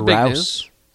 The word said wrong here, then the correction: Roos: Roos. Dang it. I knew I was saying it wrong.